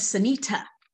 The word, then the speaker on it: Synitta: My name, for those who don't know me, my name is Synitta. Not two D's, two T's, Synitta.